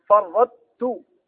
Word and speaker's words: فرطت: هذه يسمى إدغام ناقص لأنه إدغام قوي في متعرج، فتبقى صفة الاستعلاء وصفة الإطباق في الطاء فرطت، ما أنطق الطاء ولكن فيها صفة الاستعلاء وصفة الإطباق تبقى فيه، طيب في الطاء.